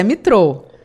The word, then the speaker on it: Mitraude: Como é que a gente pronuncia? Porque escreve Mitraude, né? Mas é Mitrou. – Exato, não, você falou certinho, fica tranquila.